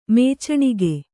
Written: ಮುಚ್ಚಣಿಕೆ

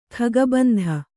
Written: ಖಗಬಂಧ

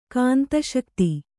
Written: ಕಾಂತಶಕ್ತಿ

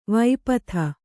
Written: ವಿಪತ್ತಿ

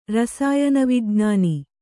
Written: ರಸಾಯನವಿಜ್ಞಾನ

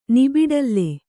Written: ನಿಬಿಡಲ್ಲೆ

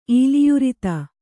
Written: ಈಲಿಯುರಿತ

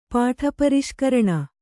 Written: ಪಾಠಪರಿಷ್ಕರಣ

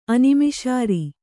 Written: ಅನಿಮಿಷಾರಿ

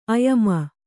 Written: ಆಯಾಮ

